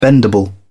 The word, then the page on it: bendable: British English